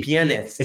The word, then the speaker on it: pianist: It should be pianist. – Pianist. – Pianist. – Yeah. – Not penis. – You got to pronounce. – Because he said penis. – Pronunciate. – He said penis with the T, not pianist. He said pianist. – Pianist. Penis. – Penis instead of pianist.